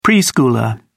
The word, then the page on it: preschooler: British English